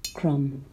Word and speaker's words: crumb: Yes, silent B. Crumb.